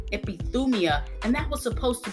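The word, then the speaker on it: epithumia (ἐπιθυμία): So John uses the Greek word epithumia. It's spelled E-P-I-T-H-U-M-I-A.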